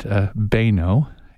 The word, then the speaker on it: Boenau: It's spelled B-O-E-N-A-U. – Right. – But you pronounce it Baino.